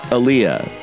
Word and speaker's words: Aaliyah: Well, what's that great song, Leia? – It's pronounced Aaliyah, and the song was called More Than a Woman.